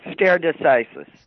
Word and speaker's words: stare decisis: That's stare decisis.